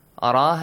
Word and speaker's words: ر: Ra has a heavy sound. Yes. It has a fatha. So you should be careful. I heard Hussein recited Alam Tara. Ra.